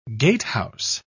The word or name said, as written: gatehouse